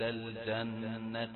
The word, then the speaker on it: الجنة: الجنة جيم شديدة، ما تقولش الجنة ولا الجنة. الجنة يبقى دي نطق غير عربي، الجنة يبقى معناها قريبة من الشين، جا وشا، لكن تأتي بالجيم محققا صفاتها، ومن صفاتها الشد.